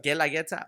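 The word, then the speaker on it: Guelaguetza: Like, and then in your restaurant, the La Galagueta. Everybody, if you guys haven't heard of La Galagueta. – Guerrageta. The U is silent.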